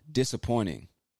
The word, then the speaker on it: disappointing: Okay, so you see there is a T in that word. Disappointing. But a lot of Americans, in my experience, we don't really pronounce the T like a hard T. It almost becomes an N or just disappears completely. So instead of saying that is disappointing, I would say that's disappointing.